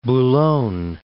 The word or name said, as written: Boulogne